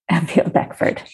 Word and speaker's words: Avil Beckford: Avil Beckford. Okay. Which is spelled exactly as you would think, listeners. A-V-I-L-B-E-C-K-F-O-R-D. – Yeah. – pretty phonetically spelled.